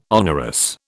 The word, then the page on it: onerous: British English